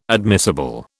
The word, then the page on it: admissible: British English